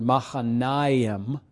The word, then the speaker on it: Mahanaim: And I've given you the kind of pronunciation of the word Mahanaim, with the accent on the second to last syllable, which means, I say this, it's kind of a strange way of saying it, dual camp.